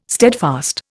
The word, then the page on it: steadfast: Indian